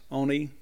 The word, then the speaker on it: Ani: Even though their Ani pronounces it more with a longer O, I guess. Oni, Logan.